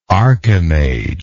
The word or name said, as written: archimage